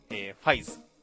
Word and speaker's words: ファイズ: まあそんな感じの、仮面ライダー 55555 と書いて、ファイズらしいです。 – ファイズ。